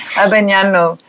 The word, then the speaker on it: Abagnano: Hopefully I'm saying that correctly. – Yes, it's a tongue twister, that one. Abagnano. is how it's pronounced. It's an Italian name.